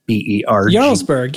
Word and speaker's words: Jarlsberg: So I'm going to go with Jarlsberg. Jarlsberg. – Jarlsberg. Yeah, I know that one. – So you said it right.